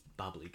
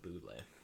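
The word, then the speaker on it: bubly: It was like, okay, it's Bubbly, guys. Stop calling it Bublé.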